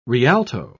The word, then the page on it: rialto: British English